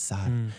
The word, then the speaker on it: Sara: I don't want to say it Sarah because there's no H and she pronounces Sara. So I was like, Sara.